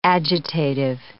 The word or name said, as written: agitative